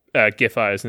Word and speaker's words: Giffey: And Giffey is right. We've had it confirmed now after like halfway through her election campaign, it was confirmed.